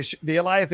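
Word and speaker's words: Alioth: Aliath, like Goliath. – That's it. The Aliath shows up and tries. By the way, the Aliath is from the comics.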